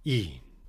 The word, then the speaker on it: e'en: And note, of course, that even here is pronounced as one syllable, e'en.